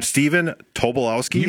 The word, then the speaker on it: Stephen Tobolowsky: So yes, I always butcher his name, Stephen Tobolowsky. – You got it.